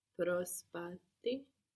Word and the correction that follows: προσπαθεί: But with the accent on the ending with this verb, no? Προσπαθεί. She's trying to do it.